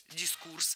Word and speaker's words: дискурс: А запомнить ударение в этом слове легко с помощью другого простого слова «курс». Курс – дискурс.